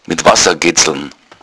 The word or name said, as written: getzln